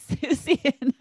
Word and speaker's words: Seussian: What and is Seussian? – Like Dr. Seuss. – Oh, Seussian.